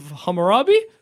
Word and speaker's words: Hammurabi: It's the Code of Hammurabi? – Yep.